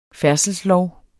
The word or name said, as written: færdselslov